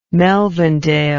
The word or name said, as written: Melvindale